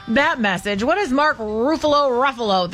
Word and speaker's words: Ruffalo: Welcome. Ruffalo, Buffalo.